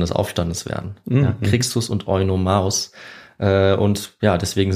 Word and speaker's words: Crixus: Crixus. – Crixus.